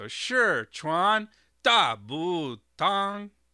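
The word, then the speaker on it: Shiquan Dabu Tang: It's Shichuan Dabutong. So Shichuan Dabutong. So that's second tone, fourth tone, third tone, first tone. Shichuan Dabutong. That is the correct or somewhat correct pronunciation, as correct as I can get it with the tone marks here.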